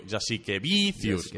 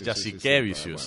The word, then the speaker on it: Jasikevičius: Tothom diu Jasikevičius, no? I és Jasikevičius.